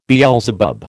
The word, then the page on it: Beelzebub: American English